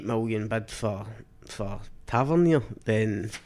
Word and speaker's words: Tavernier: So I've had Tavernier down pat for ages.